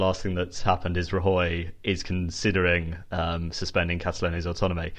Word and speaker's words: Rajoy: The head of the Catalonian government has said he's going to declare independence, and now the Spanish Prime Minister Mariano Rajoy has said that he's thinking about suspending Catalonia's autonomy. – Sorry, I laughed at your pronunciation. – Yeah, it's Rajoy.